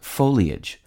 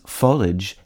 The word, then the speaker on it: foliage: Most commonly accepted is the trisyllabic foliage. However, there's no denying that the pronunciations foliage and foliage have also staked their claim. The first of these disputed pronunciations is consistent with the pronunciation of the I-A-G-E ending in marriage and carriage.